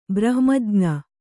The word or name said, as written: ಬ್ರಹ್ಮಜ್ಞ